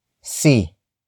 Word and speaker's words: si: The next word is si.